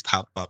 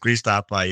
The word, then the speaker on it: Thapa: And I remember still vividly, like every night, and my name is Thapa. My name is Thapa.